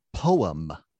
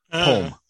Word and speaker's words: poem: One of the things that also I was noticing on this is, it's not too surprising how much Diane accentuates the two syllables in poem, but Ted Danson was almost deliberately trying to shorten it into a single syllable poem.